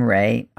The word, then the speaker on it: Rae: As an adult, I like the name Ray. I spell it R-A-E, and it has a really good vibe.